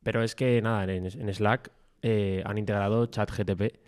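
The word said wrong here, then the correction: ChatGPT: GPT, GPT. – no lo digo mal.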